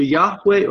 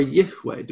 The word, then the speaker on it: Yahweh: The Septuagint, the Greek translation of the Hebrew, 200 years before Jesus, when you come to the divine name, which would be pronounced either Yahweh or Yihweh, depending on the verbal form you choose, is translated...